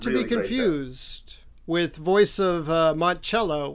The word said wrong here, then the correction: Monticello: which has a lot of slavery. – Monticello? – I forgot the middle syllable.